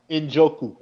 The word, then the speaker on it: Njoku: How you say it? The tight end for the Browns. I always botch that last name. – I would say Njoku. – Njoku? And that's what I would say.